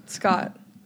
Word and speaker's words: Skjodt: I want to confirm it's Scott, right? – Yes. – Okay. – I was like, I hope you know how to say my last name. – Molly Turner tried to correct me. She said it was Skjodt. – Oh, no. Scott.